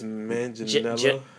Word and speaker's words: Manganiello: Let's start with the reveal that Joe Manganiello, you want to pronounce that last name for me? – I think it's Manganiello.